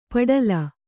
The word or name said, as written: ಪಡಲ